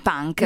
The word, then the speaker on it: Punke: Ricordiamo che l'autore è Michael Punke, si legge proprio così perché è originario tedesco, insomma. – Quindi non punk come i punk. – No, punk. Proprio con la E finale.